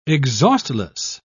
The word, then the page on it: exhaustless: British English